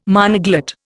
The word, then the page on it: monoglot: Indian